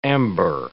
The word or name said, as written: amber